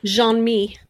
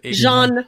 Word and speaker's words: Jean-Mi: Jean-Mi. – Is it Jean-Mi? I thought it was Jean-Mi. Oh, okay. Jean, like J-E-A-N, the French pronunciation, and then Mi, M-I.